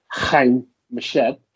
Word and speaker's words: Haim Eshed: but the head, well, the former head of space security in Israel, Haim Eshed, because that's how you pronounce it. I looked it up and I asked one of my friends and they confirmed it.